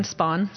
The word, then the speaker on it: Spahn: Warren Spahn. – Spahn, yeah, yeah.